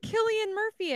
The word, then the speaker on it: Cillian Murphy: So, oh, it's got Cillian Murphy in it.